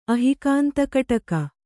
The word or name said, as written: ಅಹಿಕಾಂತಕಟಕ